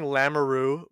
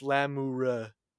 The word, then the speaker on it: Lamoureux: Also, like, everyone was saying Lamoureux, which is definitely the wrong pronunciation, but I heard it so much that I accidentally said it also. It's Lamoureux.